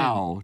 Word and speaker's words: Dow: Dow. Tony Dow was Wally Cleaver, also a friend of the show. She pronounced it Tony Doe, which I believe was a guy who posted on the fan page and called himself Tony Doe.